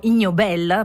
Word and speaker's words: Ig Nobel: E la 36esima edizione della cerimonia dei premi Ig Nobel, o Ig Nobel, perché l'accento andrebbe lì, si terrà proprio domani, per la prima volta in 36 anni.